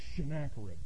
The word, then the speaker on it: Sennacherib: But you got to pronounce it Sennacherib, you know? You got to get that sh in there.